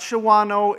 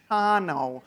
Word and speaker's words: Shawano: It's not Shawano, it's Shano.